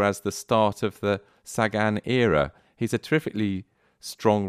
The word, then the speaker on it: Sagan: Have you figured out what the correct Slovak pronunciation is? It's Sagan, I would imagine. – I think it's Sagan, yes.